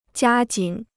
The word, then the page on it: 加紧: jiā jǐn